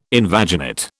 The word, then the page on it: invaginate: British English